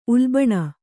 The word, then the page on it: ಉಲ್ಬಣ: ulbaṇa